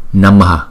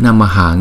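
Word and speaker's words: namaḥ: Thì đáng lẽ chữ này nằm một mình, chúng ta đọc là Namaha, Namaha, Namaha, Namaha. Nhưng vì ngoài sau chữ này còn chữ nữa, cho nên chúng ta đọc chữ Namaha nghe nó cồng kềnh quá, thì chúng ta dứt cái hơi, chúng ta đọc là Namaha, Namaha, Namaha. Nó vậy đó.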